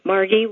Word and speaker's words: Margie: Hello, my name is Margie Preuss. Margie with a hard G and Preuss like voice.